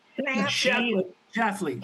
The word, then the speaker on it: Schlafly: Activist Phyllis Schlafly, that's a tough name to pronounce. – Schlafly.